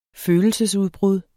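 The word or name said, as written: Følelsesudbrud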